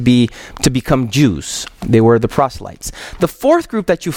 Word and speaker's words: proselytes: Is that how you pronounce it? Proselytes? Proselytes.